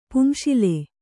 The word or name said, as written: ಪುಂಶಿಲೆ